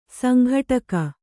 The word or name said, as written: ಸಂಘಟಕಿ